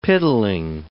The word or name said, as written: piddling